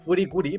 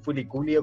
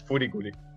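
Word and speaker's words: Furikuri (フリクリ): la serie, que la serie, si uno la busca, la tiene que jugar como FLCL el nombre en japonés es Furikuri, pero lo, cuando lo pronuncia en inglés es claro. Entonces todos la conocen como furikuli o como FLCL, pero es furikuri.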